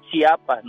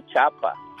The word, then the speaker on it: Chiapas: Es Chiapas, no Chapa.